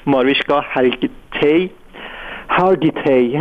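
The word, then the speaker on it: Mariska Hargitay: خب خیلی اون کسی که براش توی مجله تایم مطلب رو نوشته ماریشکا اسمش رو نمیتونم خوب تلفظ کنم، اسم بگیر امین هنرپیشه معروف آمریکایی ماریشکا هارگیتی اسمش اینجوری تلفظ میشه، این برای تیلر سویفت توی مجله تایم نوشته که گفته که خیلی مستعد پدیده موسیقی پاپ.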